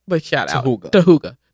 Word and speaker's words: Hooga: And now literally everything, like the invoices, the t-shirts, everything like pronounced Hooga. H-O-O-G-A. – What if we're still pronouncing it wrong, though? – How else can you say H-O-O-G-A? – Hey.